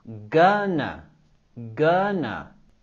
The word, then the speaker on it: gonna: Con la palabra gonna, queremos subir la entonación en la primera sílaba. Gonna, gonna. Tenemos el sonido vocálico y el sonido schwa. Gonna, gonna.